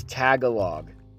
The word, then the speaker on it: Tagalog: It's Tagalog. Tagalog. That's how you say it.